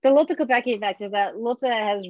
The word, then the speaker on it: Kopecky: how do you say Lotte's last name? Kopek? Kopecky. Kopecki. It's the Kopecky effect.